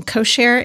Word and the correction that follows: Koshare: Indian dancers? Koshare. Koshare. Koshare, of course. – That sounds much more accurate.